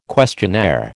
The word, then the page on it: questionnaire: American English